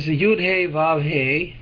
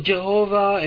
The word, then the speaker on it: Yud Hey Vav Hey: So the acronym of he will be he is and he was is Yud Hey Vav Hey, which is should, and we discussed it last time how to pronounce it. You pronounce it as I just said, acronym. It's not Jehovah and no Yehovah and no Yehovah and no vowels. Yud Hey Vav Hey. That's the name.